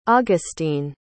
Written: augustine

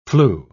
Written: flue

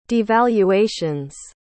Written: devaluations

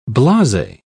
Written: blase

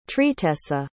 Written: tristessa

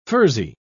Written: furzy

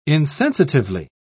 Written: insensitively

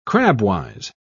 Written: crabwise

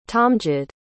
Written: tamjid

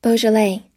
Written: beaujolais

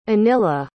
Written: Anila

